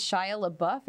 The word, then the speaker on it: Shia LaBeouf: But also, I mean, there's this movie called Peanut Butter Falcon, which is Shia LaBeouf.